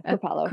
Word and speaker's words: Kripalu: Was that, is it Kripalu? I never know if I'm saying it right. – Yeah. Kripalu.